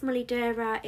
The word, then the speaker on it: Muladhara: Pronounced Muladhara, it is a lotus with four petals, and it is our basic core needs for survival.